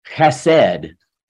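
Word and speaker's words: chesed (חסד): That Hebrew word is chesed, and I'm purposely trying to do the chesed. Sometimes it's spelled H-E-S-E-D, but a lot of times you see it with a C-H-E-S-E-D because it's, you know, they use the term guttural. Hebrew has that kind of thing. Chesed means kindness.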